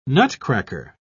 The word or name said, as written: nutcracker